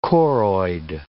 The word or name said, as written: choroid